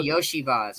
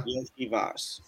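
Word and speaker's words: Iosivas: It's spelled with a Y, but it's pronounced Yo. Like it's it's Hawaiian. – Yeah, no, he's fine. – Yoshivas.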